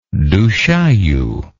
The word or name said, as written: du chaillu